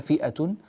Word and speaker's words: فئة: وكذلك الحال في كلمة فئة، هذه الكشيدة فئة ليست فية وإنما فئة، ولا تبدل هذه الهمزة في رواية ورش. طيب